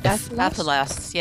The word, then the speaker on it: Athelas: Athelas, yes.